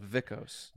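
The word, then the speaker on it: Vikos: Their B, a.k.a. beta, would be pronounced Vita in English. So it's not called Bicos, it's called Vicos.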